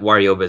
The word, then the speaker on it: Waryoba: And that Waryoba is spelled W-A-R-Y-O-B-A.